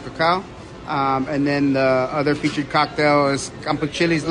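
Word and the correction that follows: cempasúchil: Lover. Campasuchil's Lover, sorry, I said that wrong.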